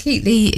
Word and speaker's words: Keightley: And Keatley is spelled K-E-I-G-H-T-L-E-Y, because I get all sorts of variations on a theme for my name.